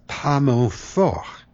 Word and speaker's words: pas mon fort: as if the French-delivered word F-O-R-T-E, fort, were the same as the Italian musical term for loud, forte. But the original French expression is pas mon fort, which not only has no E on the end to pronounce, it has a silent T as well.